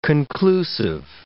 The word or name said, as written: conclusive